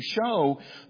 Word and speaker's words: shew: And so we see, for as oft as you eat this bread and drink this cup, ye do show the Lord's death till he come. A little side note on that word, it's shew, and it's pronounced that way. I'll say show,